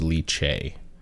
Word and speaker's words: lychee: I don't know how it's pronounced. L-Y-C-H-E-E. – Lychee? Yeah. You're right. – Lychee? – Yeah, I believe so. – Well, no. The only reason I'm torn is because I remember, like, Googling it a long time ago, and it's got a weird pronunciation, and I think it's Lychee. – Yeah, it is Lychee.